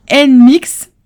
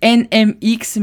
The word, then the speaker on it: NMIXX: N-Mix, voilà. Voilà, parce que d'habitude je dis les N X mais c'est les N-Mix euh, tout simplement.